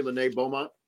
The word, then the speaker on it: Lene Beaumont: I'm just curious, have you had a chance to see in person or on some film the girl out of Illinois, Lene Beaumont? I believe I said her name right. – You did.